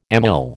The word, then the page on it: amyl: American English